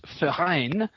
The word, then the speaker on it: Verein: Actually, I should give the Swiss German pronunciation. It's Swiss Verein.